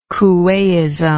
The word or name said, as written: coueism